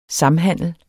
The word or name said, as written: samhandel